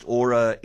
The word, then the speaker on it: Oura: It's spelt O-U-R-A, but in English it's pronounced Aura.